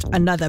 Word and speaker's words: ANOTR: Another, it's the one that's spelled A-N-O-T-R, right? And it's pronounced Another.